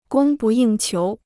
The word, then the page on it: 供不应求: gōng bù yìng qiú